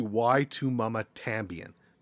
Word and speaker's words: Y Tu Mamá También: It's actually Y Tu Mamá También. That's how you're supposed to say it.